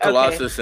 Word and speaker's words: Colossus: The next couple, I hope I don't mispronounce this, but Colossus and Kitty Pryde. Is that is that correct? Did I yeah, yeah. Right? Colossus okay.